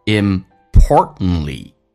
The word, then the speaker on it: importantly: T 跟 L 在一起的时候一般 T 不发音，所以会发成 importantly。同时那么经常我们会发现中间那个 T 也不发音，因为 T 跟 N 在一起的时候美式发音 T 可以不发音，所以这个单词就变成 importantly, most importantly, And most importantly, don't forget to 降调读 don't forget to listen to your heart, 降调。